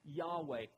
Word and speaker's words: Yahweh: But we'll, we, have come to it by way of tradition in English, uh, uh, uh, pronouncing that Yahweh.